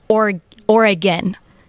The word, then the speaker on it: Oregon: So how do the locals say it? – Yeah, it's Oregon.